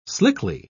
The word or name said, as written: slickly